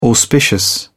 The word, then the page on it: auspicious: British English